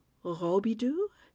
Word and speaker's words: Robidoux: It's R-O-B-I-D-O-U-X, so probably pronounced something like Robidoux, but the locals call it the Robidoux, and it's gorgeous.